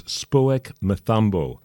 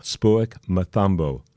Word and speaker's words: Spoek Mathambo: Spoek Mathambo. That's spelled S-P-O-E-K for the first name, Spoek, and then M-A-T-H-A-M-B-O, Spoek Mathambo.